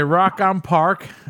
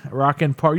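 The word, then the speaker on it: Rock im Park: Okay, Rock on Park. Rock in Park. You put M. Rock on Park. – It's Rock in Park. It's German.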